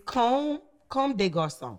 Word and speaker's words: Comme des Garçons: No, it's Comme des Garçons. – Comme is not. – Comme des Garçons. – Interesting. – It's Comme des Garçons.